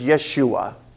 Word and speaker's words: Yeshua: And actually the Jews would not pronounce it Joshua. The way it would be pronounced in the Hebrew is Yeshua. J's were almost always a Y.